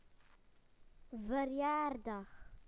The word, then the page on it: birthday: British English